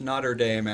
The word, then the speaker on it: Notre Dame: It's pronounced Notre Dame, actually.